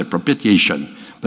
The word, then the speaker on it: propitiation: It's spelled P-R-O-P-I-T-I-A-T-I-O-N. It looks like propitiated. It's hard to say propitiation, but it's propitiation is how you say it.